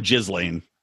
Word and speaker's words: Ghislaine: I just call her Ghislaine. Like Jizz Lane.